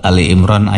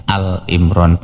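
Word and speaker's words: Ali Imran (آل عمران): Kemudian selanjutnya dalam surat Ali Imran ayat 41, ya, banyak orang salah membaca. Mereka membacanya Al-Imran, padahal Imran itu kan nama orang. Dalam bahasa Arab, nama orang ini ndak usah ditakrif, ndak usah diberi alif lam.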